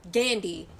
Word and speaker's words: Gandhi: Let me get it right. Gandhi, not an R, Gandhi.